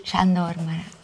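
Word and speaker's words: Sándor Márai: Sandor Marai.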